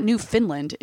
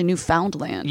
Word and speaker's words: Newfoundland: But it's not Newfoundland. It's actually Newfoundland.